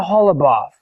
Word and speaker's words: Holubov: Hannah's name, he met a girl named Hannah Holubov. Uh, that's how they say it in Russian, related to Golubov, an uh, English name that you may be familiar with.